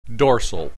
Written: dorsal

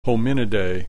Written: Hominidae

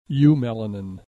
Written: eumelanin

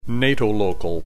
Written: natolocal

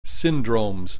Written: syndromes

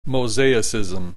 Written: mosaicism

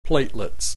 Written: platelets

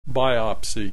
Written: biopsy

